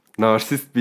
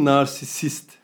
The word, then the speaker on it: narsisist: Abi bir ıslak mendil için fazla özgüvenli bu narsist bir ıslak mendili olabilir yani. Abi narsizm değilmiş aslında onun telaffuzu. – Narsisizm. Narsisizm. – Narsist değil, narsisist.